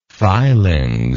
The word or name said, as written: filings